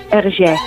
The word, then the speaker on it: Hergé: permutándolas a RG, de manera que pronunciadas en francés sonase RG. Sin embargo, la licencia creativa de Hergé terminó con este detalle,